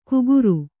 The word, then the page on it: ಕುಗುರು: kuguru